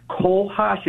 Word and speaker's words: cohosh: It's a black cohosh. It's spelled C-O-H-O-S-H.